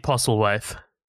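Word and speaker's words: Postlethwaite: Is that, like, Peter Postlewaith? Do you want me to say it? – Peter Postlewaith or something? – Yeah, Pete Postlewaith. – Pete Postlewaith. Okay, good.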